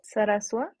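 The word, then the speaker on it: Zarazúa: What's the pronunciation? – Saraswa.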